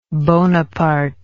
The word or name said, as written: bonapartean